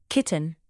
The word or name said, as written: kitten